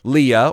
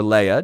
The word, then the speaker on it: Leah: He's tricked him, and he swapped out his eldest daughter, Leah or Leah, depending on your preferred pronunciation, and that's who he marries.